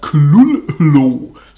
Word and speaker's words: Cthulhu: And the first syllable is not unlike Klull in sound, hence the H represents the guttural thickness. Das heißt, dieses H in, in Klullu, das spricht man gar nicht.